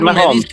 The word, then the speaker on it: Mahomes: Pat Mahomes.